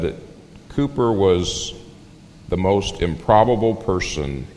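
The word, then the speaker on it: Cowper: One day, William Cooper, and that is how you pronounce it, though it's not spelled that way, but William Cooper showed up in town.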